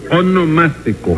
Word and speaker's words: onomástico: Su monoelástico. – No, su onomástico.